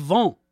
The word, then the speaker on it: Vingt: You know, I'm not going to say Vingt, V-I-N-G-T. I'm going to do my best to that French pronunciation, the Club Vent Orchestra, and a tune called Mandy and Me.